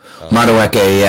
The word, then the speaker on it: Madueke: I can't remember how to pronounce his name. Madueke, I think. – Madueke, I think that's correct.